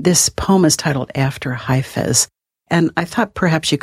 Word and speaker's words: Hafez: Emerson said that Hafez is the poet's poet.